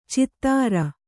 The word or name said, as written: ಛಿದ್ರ